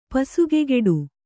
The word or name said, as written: ಪಸುಗೆಗೆಡು